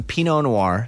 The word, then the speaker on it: Pinot Noir: We wanted some Pinot Noir.